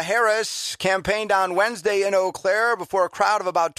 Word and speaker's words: Eau Claire: Hello, Eau Claire.